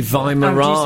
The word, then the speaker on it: Weimaraners: Tartan PJs and three Y Mariners hanging off them waiting for their walk. – Three Weimaraners.